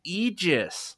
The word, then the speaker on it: Aegis: And if you push learn to pronounce this thing, they call it, and what Destiny pronounced it was Aegis.